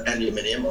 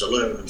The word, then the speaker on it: aluminium: We said, would you like to win an aluminium, or your pronunciation is aluminum, fence?